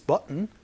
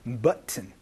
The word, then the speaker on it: button: What is this button for? 이렇게 물어보시면 되겠습니다. 여기에서의 버튼이라는 발음은요. button 이렇게 얘기를 하거나 혹은 button 해서 은 소리로 바뀔 수도 있으니까 요거 조금 주의해 주시면서 연습 한번 해보도록 하겠습니다.